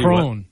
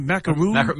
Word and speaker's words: macaron: There's no oo. – I've never heard it pronounced that way. – Only one O. So I always thought it was macaroon. – Macaron, not macaroon.